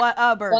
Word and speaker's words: Whataburger: Is it called Whataburger or no, what a burger? – What a burger. – Okay. – What a burger. What a burger. – But we pronounce it Whataburger.